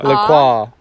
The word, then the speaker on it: La Croix: It is not LaCroix. – Right. – Don't say LaCroix. That is wrong. That is the worst pronunciation. It is La Croix with an A at the end. Say it like that, people. – With an La Croix. – A at the end. – Well, no, but an A sound.